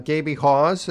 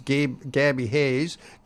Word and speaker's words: Gaby Haas: Clarence Plouffe was an excellent Edmontonian guitar player, recording with Gaby Hawes. It's spelled G-A-B-Y-H-A-A-S. Sometimes people mispronounce that as Gabby Hayes. Gabby Hayes was a Hollywood actor, but this is Gaby Hawes, who also is famous to record collectors for owning a music store, a record store in Edmonton.